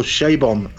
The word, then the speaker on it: Chabon: I listened to a podcast with uh, Michael, Sh- and it's Michael Shabon. I've I've since found out.